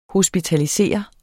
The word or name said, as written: HOSPITALISERE